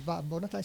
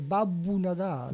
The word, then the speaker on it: Babbu Natale: figli sempre mi, mi, si prendono in giro, dicono papà non si dice Babbo Natale, Babbo Natale si dice Babbu Natale.